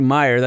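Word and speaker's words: Meijer: It should be Meyer. That's a Midwest fuel station.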